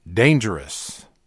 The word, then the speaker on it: Dangeroux: I needed a pronunciation guide, so I sent an email to a Chicago-based blues promoter, and I said, how do I pronounce D-A-N-G-E-R-O-U-X? It's like danger, and at the end, the last three letters are O-U-X. And he said, oh, I know how to pronounce it. Mike pronounces it dangerous.